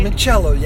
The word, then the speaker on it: limoncello: But it also had like limoncello. Am I saying that right? – Limoncello, yes.